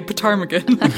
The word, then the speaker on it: ptarmigan: Do you know that's spelled with a P? – What? Yes, that's P-T. Why I had trouble with it, because I did want to say ptarmigan.